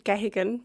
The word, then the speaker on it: Geoghegan: Well, I say Bridget Geoghegan.